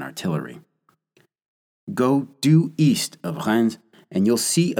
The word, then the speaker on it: Reims: And if you're not currently driving, set your Google Maps to the city of Rennes, French pronunciation there. Hang on a second, I'll spell it, because it sounds fairly different from how it's spelled. R-E-I-M-S.